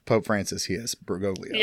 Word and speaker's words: Bergoglio: It's just Bergoglio. Am I pronouncing that right? – Yes, Bergoglio.